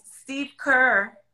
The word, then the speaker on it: Steve Kerr: Who like tell the people here, because like Steve Kerr, I said his name right?